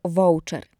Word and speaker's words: voucher: Spolonizowaną i częstą wymowę voucher można więc uznać za poprawną.